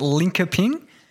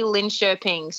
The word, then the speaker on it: Linköping: Is it Linköping? – Close. Everyone says that. I said that when I first signed there until I got over there and realised I was saying it wrong. But no, it's actually Linköping. So it's sort of, it's like, yeah, it's the K turns into a sh sound.